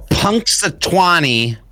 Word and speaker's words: Punxsutawney: Punxsutawney Phil.